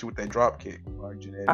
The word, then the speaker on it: Marty Jannetty: I'm gonna Marty Jannetty your ass. I'm just trying to get. I'm so, Shawn Michaels. Yes, I am. – No, you just said Marty Jannetty.